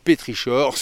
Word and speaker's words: pétrichor: Dans la racine grecque, ichor, le che se prononce avec le son K. Le mot pétrichor se prononce donc pétrichor en français.